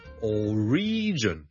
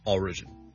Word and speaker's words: origin: Number two 咧，如果讀成 origin 咧就會怪怪地啦，因為個重音其實 origin o r i g i n 個來源。我們的重音是第一個音節的，所以 single law or origin, origin。